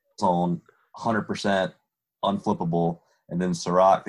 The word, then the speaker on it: Serac: Well, the one path to failure to me is that um, what's his name, Serac? Is that how you say it? – Yeah, Serac, Serac, and then Serac, Caleb.